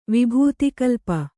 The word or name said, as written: ವಿಭೂತಿಕಲ್ಪ